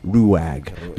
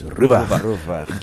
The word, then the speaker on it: Ruwag: I've heard it pronounced as Ruag. – Because yeah, we call it like do you get that? – Yeah, I understand. Yeah. – Because that's the spelling. Ruwag. Ruwag. No, it's Rubach.